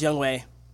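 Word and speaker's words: Younghoe: My man Young Ho from the Falcons, he got three. – Young Wei. – Oh. Am I pronouncing it wrong? – Yeah, it's pronounced Young Wei.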